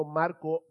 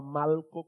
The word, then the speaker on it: Malco: y no estoy pronunciando Marco mal, es que se llamaba Malco con L.